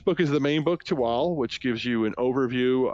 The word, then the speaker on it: Tehuatl: It's spelled T-E-H-U-A-T-L, which would probably make you think it's Tehuatl, but it's not. It's Tehuatl.